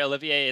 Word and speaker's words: Olivier: Olivier. That's how we agreed to pronounce it, right? Olivier, it's like Lawrence.